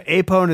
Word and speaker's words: Apone: Apone? Is that how you say it? – Yeah. – Yeah, Apone is killed.